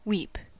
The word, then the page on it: weep: American English